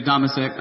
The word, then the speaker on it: Domasek: Domasek, I always want to make it a long O.